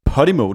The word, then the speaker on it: Podimo: Podimo, det er også sådan, man udtaler det. – Det er podcast, og så er det emo.